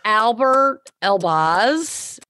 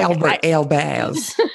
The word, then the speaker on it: Alber Elbaz: Albert Elbaz. – Not Albert, dear. Albert. He's French.